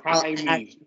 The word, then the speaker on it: Jaime: Jamie drops fucking 40. – It's Jaime.